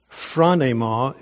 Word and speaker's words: phronema: I don't know that this is going to add a lot to this, but there is a Greek word, phronema is how it's pronounced.